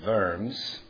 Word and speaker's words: Worms: The Germans pronounce it Worms. It's a W, so we say Worms, but it's Worms.